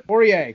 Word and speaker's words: Poirier: Is that how you pronounce it? – Poirier. – Close enough. Poirier. French enough.